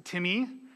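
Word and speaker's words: Timmy (τιμή): They're translating the same word, which is Latinized as T-I-M-E. It's pronounced Timmy, even though it looks like time. There is no correlation between the Greek word, T-I-M-E, Latinized, and our English word time.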